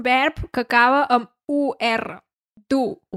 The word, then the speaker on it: dur: Du, o sigui, du, D-U-R, però pronunciem du.